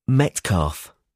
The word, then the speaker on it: Metcalfe: Metcalf. – Metcalf. How do you spell that? – M-E-T-C-A-L-F-E. – M-E-T-C-A-L-F-E.